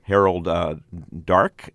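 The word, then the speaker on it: Harold Darke: It has Harold Dark. Am I pronouncing that right? – You are, yeah.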